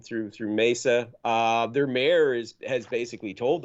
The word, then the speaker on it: Mesa: I think it's Mesa. Yeah. Sorry, I'm Spanish pronunciation. But Mesa, yeah.